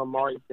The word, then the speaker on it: Amari: Official Amari J. – Official Amari J. – Hell yeah. – You feel me? That's spelled A-M-A-R-I, means it's a letter J.